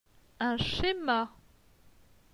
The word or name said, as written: Un schema